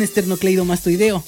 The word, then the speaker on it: esternocleidomastoideo: Oigan, que, bueno, nos vamos con esternocleidomastoideo.